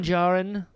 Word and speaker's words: Djarin: So many Din Djarin. – Mm-hmm. – The D is silent.